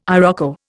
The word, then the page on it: iroko: Indian